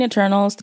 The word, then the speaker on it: Eternals: 16, Eternals. Did I say that right? – Yep. – Yes.